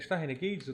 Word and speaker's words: Star Renegades: Eu joguei um jogo chamado, chamado Star Renegade também, que eu, é foi uma demo que eu, que eu joguei. É, é, Star Renegades, eu tô falando certo? Não, tô, tô falando certo sim.